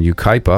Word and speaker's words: Yucaipa: And now Jeff Miller Sachs, who's out on the West Coast, and he's in Yucaipa, I believe. Am I saying that correctly, yes.